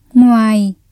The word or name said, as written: ngoài